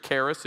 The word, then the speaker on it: charis: The name is charis in the Greek. Charis, if you want to pronounce it wrong.